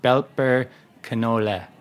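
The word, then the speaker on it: Belper Knolle: So German's a very phonetic language, and... As far as I know, E's are not pronounced like they are in English. They're like eh sounding. So it's like Belper Canole.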